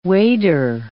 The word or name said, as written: wader